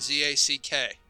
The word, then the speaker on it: Zack: My name is spelled Zack.